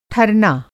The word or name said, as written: ठरना